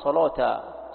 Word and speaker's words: صلوتا: تنطق عند اليهود بالعبرية الصلوات، ينطقونها صلوتا، صلوتا معناها موضع العبادة، الكنيسة.